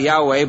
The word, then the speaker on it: Yahweh: Now, we pronounce that Yahweh, but I tell you, that's just our best guess.